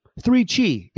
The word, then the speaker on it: 3Chi: 3Chi, it rhymes, is the industry leader in Delta 8 THC products.